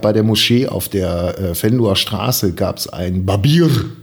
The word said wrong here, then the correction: Berber: Oder Ber Babir, sagt man, ne? Habe ich das gut ausgesprochen? Also du meinst das türkische Wort? Ja. Äh, Berber. Berber. Ja, genau, das war's.